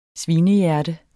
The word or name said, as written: svinehjerte